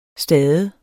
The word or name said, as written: stade